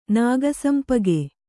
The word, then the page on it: ನಾಗಸಂಪಿಗೆ: nāga sampige